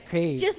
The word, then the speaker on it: Kyiv: I said it correctly. Cave. – It's like a cave. Think of a cave. – Okay. – And just a cave, but just kind of make it smooth.